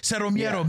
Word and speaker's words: Cero Miedo: And I would not be shocked if people around were like, "Oh, Cero, zero fear, Cero Miedo, Miedo, Miedo." That's how you say it, like, because it felt like everyone got the memo right at the end.